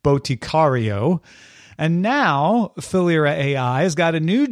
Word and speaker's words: Philyra: Last October, IBM announced Thalyra AI. It's spelled P-H-I-L-Y-R-A.